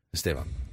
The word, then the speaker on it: Esteban: B, but you pronounce it a V. Esteban.